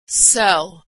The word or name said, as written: sell